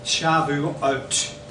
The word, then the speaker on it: Shavuot: This weekend it's called, in Hebrew, Shavuot. That's how it's pronounced.